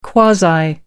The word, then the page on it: quasi: American English